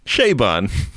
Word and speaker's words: Chabon: How do I get dragged every time I correctly pronounce something in French, but you can get away with calling somebody Michael Chabon? that's like calling it Target. – How do I really pronounce it? – Chabon.